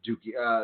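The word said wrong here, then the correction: Douki: Dukai, is that how you say it? – Doki. – Doki, there we go.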